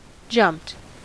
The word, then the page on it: jumped: American English